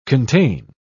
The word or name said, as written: contain